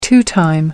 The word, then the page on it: two-time: American English